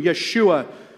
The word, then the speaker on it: Yeshua: The name Jesus in Hebrew, Yeshua, most similarly pronounced to Joshua, only with a Y.